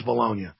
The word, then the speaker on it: Bologna: They pronounce it Bologna. It's Bologna bombing. And the tomato, whatever. They're the Italians. They call it Bologna. It's Bologna.